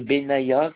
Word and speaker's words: Benyahia: Donc, je m'appelle monsieur Benaya, ça s'écrit B-E-N-Y-A-H-I-A, Eric. – Alors, B-N-Y-A-H-I-A.